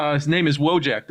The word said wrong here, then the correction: Wojtek: As from all the videos that I watched, that when people were actually Polish and speaking this name, it was Wojtek. – The W and the V and the, mm-hmm. yeah. Okay. – So that's how it's pronounced, is Wojtek.